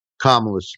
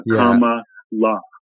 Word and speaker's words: Kamala: And, well, we were all wearing these pins, and these, you know, pins showed a comma followed by L-A. Comma La.